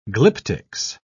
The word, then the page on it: glyptics: British English